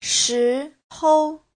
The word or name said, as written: shi2hou5